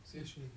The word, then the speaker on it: satiety: How do you pronounce that word? Satiety. Okay, thank you.